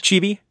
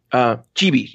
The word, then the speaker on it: Chibi: Chibi? – Chibi, yeah.